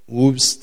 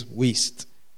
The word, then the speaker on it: Wuest: So, um, you know, you have to know that it's pronounced that way. Otherwise, you just call it Whoops or whatever, you know, but apparently it is Wuest.